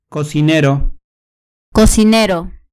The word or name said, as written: cocinero